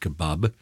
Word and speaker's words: kebab: And so we've put an A because we want to say kebab, which is how they say it, kebab. And an American would say, spell kebab, K-E-B-O-B.